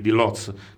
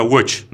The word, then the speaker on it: Łódź: la famosa scuola di Lodz, che poi in polacco si pronuncia Łódź, no? – Vabbè.